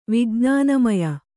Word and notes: ವಿಜ್ಞಾನಮಯ: vijṇāna maya

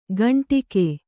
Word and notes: ಘಂಟಿಕೆ: Kannada

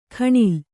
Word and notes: ಘಣಲು: ghaṇalu

